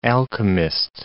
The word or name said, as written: alchemist